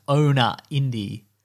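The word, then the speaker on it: ownaindi: So I think the website is supposed to be pronounced owner indie.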